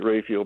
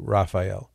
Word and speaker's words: Raphael: Just remember that Raphael is an anglicized pronunciation of the Hebrew Raphael. So if you were to look at that word in Latin or Hebrew or Greek, it would not be pronounced Raphael. That's an English way of pronouncing it. And there's nothing wrong with it. But it's not the original pronunciation of the name.